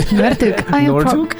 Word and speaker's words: Noordhoek: we have Cafe Roux, which is in Noordhoek. Did I say that right? – Noordhoek.